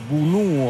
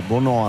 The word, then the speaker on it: Bounou: Bounou o Bono a seconda che si prenda la pronuncia francese o quella araba, Bono è il suo soprannome, referto però è Bounou.